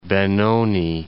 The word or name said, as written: Benoni